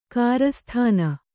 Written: ಕಿರಿಸ್ತಾನ

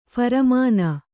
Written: ಫರ್ಮಾನ್

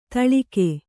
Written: ಧೂಳಿಕ

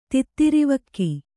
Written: ತಿತ್ತಿರಿವಕ್ಕಿ

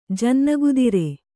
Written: ಜನ್ನಗುದಿರೆ

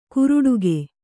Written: ಕುರುಡುಗೆ

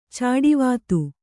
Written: ಚಾಡಿವಾತು

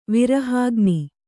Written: ವಿರಹಾಗ್ನಿ

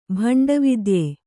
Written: ಭಂಡವಿದ್ಯೆ

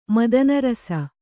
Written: ಮದನರಸ

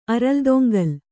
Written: ಅರಲ್ದೊಂಗಲ್

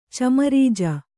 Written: ಚಮರಜ

